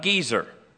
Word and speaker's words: Gezer: In verse 15, one of the cities that Solomon had to build was the city of Gezer. All right? I don't know if it was old Gezer or new Gezer, but Gezer. And if you'll look, there's a long E sound on the first E.